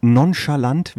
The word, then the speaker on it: nonchalant: Das Fremdwort nonchalant, habe ich das richtig ausgesprochen? – Nonchalant heißt das doch.